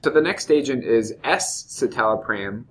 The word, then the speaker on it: escitalopram: And I've heard some pretty interesting pronunciations of escitalopram, but it's really the letter S and then citalopram.